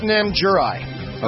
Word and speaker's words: Namjurie: Okay, it's pronounced Namjurie. – Okay. – N-A-M-J-U-R-I-E.